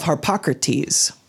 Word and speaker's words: Harpocrates: This is known as the vow of Harpocrates.